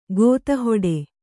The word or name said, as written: ಗೋತಿಹೊಡೆ